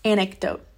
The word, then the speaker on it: anecdote: Let me jump into the story of this weekend because this is a perfect antidote for this. Anecdote. Did I say that right? Anecdote? Yeah. Not antidote. Anecdote.